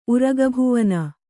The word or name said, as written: ಉರಗಭುವನ